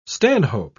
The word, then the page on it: stanhope: British English